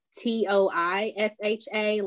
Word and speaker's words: Toisha: Um, my first name is spelled T O I S H A.